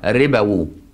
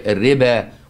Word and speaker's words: الربا: هي الربا والواو دي لا تنطق سايلنت، زي في حروف كده سايلنت ان انجلش، واخد بالك؟ يعني ايه سايلنت؟ يعني تكتبها وما تنطقهاش.